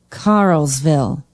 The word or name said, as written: carlsville